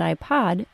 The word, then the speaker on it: iPod: The words iPad and iPod are identical except that iPad is pronounced with a short A and iPod is pronounced with a short O.